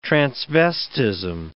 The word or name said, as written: transvestism